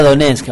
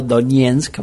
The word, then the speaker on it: Donetsk: Ah, lo dije bien. Ah, sí, yo lo digo con acento ruso, perdón. Bueno, yo le decía Donetsk, ahora le digo Donetsk para pronunciarla más o menos mejor.